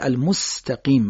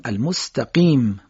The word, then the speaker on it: المستقیم: بگیم قل هو الله احد، یا کلمه المستقیم مثلا دقت کنید، بعضیا میگن المستقیم، سین رو صاد تلفظ میکنن، یا میگن المستقیم، فتحه ت رو میکشن، اینها صحیح نیستش.